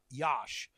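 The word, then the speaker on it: Iași: I also want to mention that there's a city called Yash, and if you ask the greatest spelling bee champ in the world to spell Yash or pronounce Yash after looking at it, it's the second biggest city in Romania.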